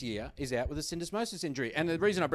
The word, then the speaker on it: syndesmosis: Syndesmosis.